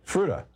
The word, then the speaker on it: Fruita: Fruita, Colorado. Am I saying that right? – Hi. It's you're close. It's Fruita, Colorado. – Fruita.